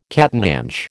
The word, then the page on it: catananche: American English